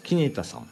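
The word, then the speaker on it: 希根太: これね、国松キネタ連鎖する息吹というね企画展ですね。国松キネタさん、キネタは希望の根っこが太いと書いてキネタさんです。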